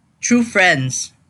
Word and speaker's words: TRVE FRNDS: They're called um, True Friends, but it's spelled differently. It's spelled T R V E F R N D S. Okay. I think that's how you pronounce their name.